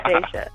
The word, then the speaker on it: Daisha: Am I saying your name right? I don't want to mess that up. – You did. You said it correct. Thank God. Yeah, Daisha.